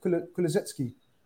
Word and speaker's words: Kulusevski: Kulusevski.